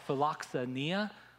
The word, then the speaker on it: philoxenia: The word Paul uses is a word that we would pronounce philoxenia, which I know is a mouthful.